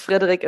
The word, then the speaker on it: Frederik: Who are Frederik and Margit? Am I saying that right? – Yeah, in German it's Frederik and Margit. – Okay. – But you were doing well, yeah.